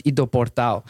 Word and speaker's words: Ido Portal: Um, and this person was Ido Portal. He's an mm. Israeli guy. And I say his name like that because that's how it is said. it is it is spelled I D O Portal P O R T A L.